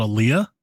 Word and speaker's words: Olija: They have another game called Alia. It's spelled Olija.